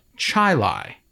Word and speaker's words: Chili: She's from a town called Chilai, New York. It looks like chili. Don't say chili. It's pronounced Chilai.